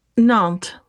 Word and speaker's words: Nantes: Nantes.